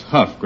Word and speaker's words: Hough: No, that's Huff, Groucho. H-O-U-G-H. – How can it be Huff? – Well, D-O-U-G-H is bow, isn't it?